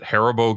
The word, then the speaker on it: Haribo: We've got Baby Ruth at number eight versus, how do you pronounce that? Is it Haribo Gummies? – Good enough. Sure.